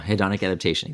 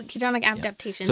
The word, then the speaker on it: hedonic adaptation: Yeah, hedonic adaptation, exactly. – Oh, hedonic adaptation.